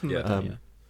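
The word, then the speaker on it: Mumbattan: Is it Mumbaton? Am I saying that right? – I think it's Mumbaton, yeah.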